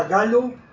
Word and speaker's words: Gallo: Camila Galo, italiano, pero se escribe con doble L, Gallo.